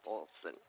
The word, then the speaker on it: Olson: My name's Karen Olson. It'd be under Ralph Olson, O-L-S-O-N.